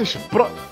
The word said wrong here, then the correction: Spotify: Isso, isso. – Spotify, Spotify.